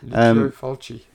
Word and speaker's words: Lucio Fulci: Directed by the Italian Lucio Fulci. Did I say that right? – Pretty much, yeah. – Did I say that right? – Pretty much, yeah. – Okay. – Lucio Fulci.